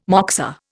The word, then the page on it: moksa: Indian